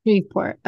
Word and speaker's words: Shreveport: Is I think it's Shreveport. – Here we go. – Shreveport.